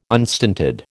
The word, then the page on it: unstinted: American English